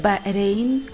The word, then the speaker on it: Bahrein: Pero en esta ciudad, ya ven, Bahrein, ya me dijeron, se pronuncia Bahrein con la R suavecita.